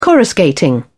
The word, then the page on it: coruscating: British English